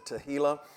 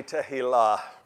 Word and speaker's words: Tehillah: Up until I looked up the pronunciation last night, I always said Tehillah. But anyway, it's supposed to be Tehillah.